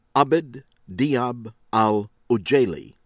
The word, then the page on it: Abed Dhiab Al-Ujaili: American English